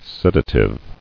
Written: Sedative

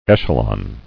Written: Echelon

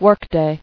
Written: Workday

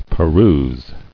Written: Perusing

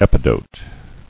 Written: Epidote

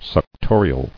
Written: Suctorial

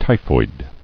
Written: Typhoid

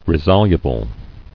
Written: Resoluble

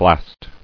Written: Blaster

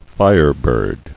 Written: Firebird

